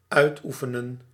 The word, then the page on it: exert: /ɪɡ.ˈzɜːt/